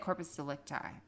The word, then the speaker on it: corpus delicti: I want to say delicti. – I, am just saying say? it the way they say it because I don't know how to say it. – Yeah. So, so on this show, they said corpus delicti. We'll go with it.